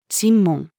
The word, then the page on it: 審問: female